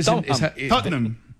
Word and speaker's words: Tottenham: Tottenham. Tottenham. Son dos sílabas.